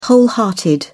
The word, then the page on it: wholehearted: British English